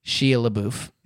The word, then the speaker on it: Shia LaBeouf: Say it correctly. Shia LaBeouf.